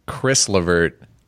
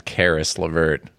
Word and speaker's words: Caris LeVert: Ken, you did have one mispronounced name. – Oh, I knew it right when I said it. Lay it on me, brother. – Chris LeVert. – Yeah. – Is in fact Caris LeVert.